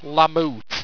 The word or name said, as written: lamoot